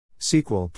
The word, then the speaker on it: SQL: SQL, pronounced SQL, has become the de facto standard language used for managing data in relational databases.